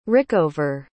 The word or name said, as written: rickover